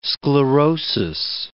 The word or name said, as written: sclerosis